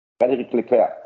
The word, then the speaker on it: Frédéric Leclerc: Frédéric Leclerc.